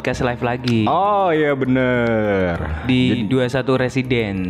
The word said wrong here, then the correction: Residence: 21 Resident. Oh 21 Bacanya Residence.